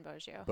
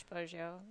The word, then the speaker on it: Boggio: So because in your last name, it's Pennington, and then how do you say the second part? – Just say Pennington Boggio. – Boggio. Okay. Yeah. All right. – It's sometimes not I'm like not sure. Pennington dash Boggio.